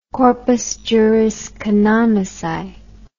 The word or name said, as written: corpus juris canonici